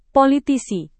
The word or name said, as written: politisi